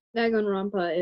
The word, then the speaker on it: Danganronpa: I don't really know how to pronounce it. – Danganronpa is how you pronounce it.